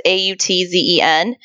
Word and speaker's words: Autzen: So I'm going to butcher the name of their stadium, but it's called like Autzen Stadium. It's Autzen and it's home of the Ducks, but it's actually named for an Oregon State alumni.